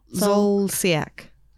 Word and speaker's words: Zolciak: Zolciak.